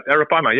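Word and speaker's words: Arapaima: Moving to another species that I know you've targeted, before, and I'm probably going to butcher the name of this, Araprima. Is that right? – No, that's right. Araprima.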